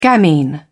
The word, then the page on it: gamine: British English